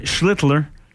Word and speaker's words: Schlittler: Un duel impitoyable, je dirais, avec Cam Schlittler. – Schlittler. J'aime ça de la manière que tu l'as très bien prononcé.